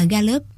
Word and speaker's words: Gallup: Năm 1935, ông thành lập một viện mà sau này cả thế giới biết đến với tên là Viện Gallup, mà phiên âm tiếng Việt thì đọc là Gallup.